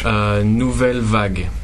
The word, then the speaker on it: Nouvelle Vague: Nouvelle Vague.